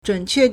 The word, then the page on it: 准确: 準確; zhǔnquè